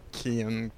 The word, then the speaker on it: Kian: This time, your Irish pronunciation of Kian wasn't up to snuff. Apparently, Kian. I forgot to tell you about that.